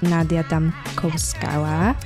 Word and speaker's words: Nadia Damkovskaya: Uh, I'm here with Nadia Damkovskaya.